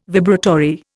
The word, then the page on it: vibratory: Indian